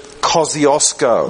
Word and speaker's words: Kosciuszko: Gdybyście przyjechali do Australii i powiedzieli, wasza najwyższa góra nazywa się Kościuszko, no Australian would believe you. – To żaden Australijczyk by wam nie uwierzył. – Because we pronounce it Kosciuszko.